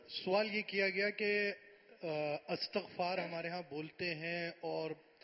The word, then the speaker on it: استغفار: ہاں, اس میں یہ ہے کہ یہ جو صحیح تلفظ ہے نا, یہ استغفار, استقبال, استغفار, اس کے وزن پر, استقبال کے وزن پر,